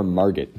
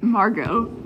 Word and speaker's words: Margot: Is that Margo or Margot? – Margo. – Margo. – Margo is spelled with a T at the end.